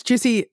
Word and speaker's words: Giusy: And it's Juicy, G-I-U-S-Y, pronounced Juicy.